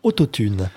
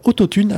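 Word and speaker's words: Autothune: Et ben Autotune, alors ça s'écrit auto comme une auto, Tune, T-H-U-N-E.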